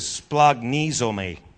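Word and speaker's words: splagnizome (σπλαγχνίζομαι): The Greek word there is splagnizome. It's spelled splagnizomai.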